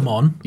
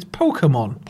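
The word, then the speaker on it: Pokémon: Pokemon? – Yes. They would evolve into what? – It's Pokemon. – Pokemon. I've always pronounced it Pokemon. I know it's Pokemon if I'm American, but I'm not.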